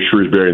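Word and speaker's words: Shrewsberry: I think Notre Dame will be a tremendous program under Micah Shrewsbury in the future.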